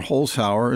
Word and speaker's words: Holzhauer: The contest could end as early as Friday if Jennings or Holzhauer, is that how you say his name? – Yeah.